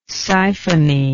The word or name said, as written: ciphony